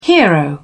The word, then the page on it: hero: British English